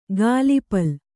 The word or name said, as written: ಗಾಲಿಪಲ್